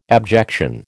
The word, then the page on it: abjection: American English